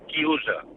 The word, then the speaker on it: chiusa: Y un momento, y quiero decirle a Fernando italiano se dice chiusa.